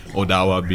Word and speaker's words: Odawa: And you go across the country and it's the same thing, like Saskatchewan or Ottawa or all these places, right? Or Anishinaabe or Cree or Odawa names. Odawa being the original pronunciation.